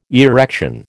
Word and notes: erection: American English

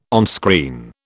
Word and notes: onscreen: British English

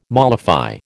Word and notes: mollify: American English

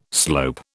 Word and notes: slope: British English

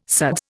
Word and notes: suds: Indian

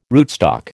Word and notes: rootstock: American English

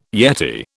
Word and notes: yeti: British English

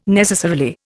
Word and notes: necessarily: Indian

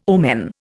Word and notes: omen: Indian

